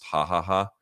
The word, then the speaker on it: Ja Ja Ja: There's a place called Ja Ja Ja. J-A-J-A-J-A, Ja Ja Ja. But it's, of course, pronounced Ha Ha Ha.